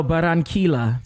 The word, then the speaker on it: Barranquilla: Barranquilla? – Okay. – No, Barranquilla.